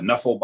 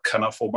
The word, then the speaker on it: Knuffle: Nuffle Bunny or Knuffle Bunny, however you want to pronounce it.